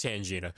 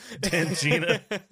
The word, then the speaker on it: Tangina: Yeah, her name's Tangina. – Tangina.